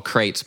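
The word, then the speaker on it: Qrates: the service is called Crates, by the way. It's spelled with a Q, so Q-U-Q-R-A-T-E-S.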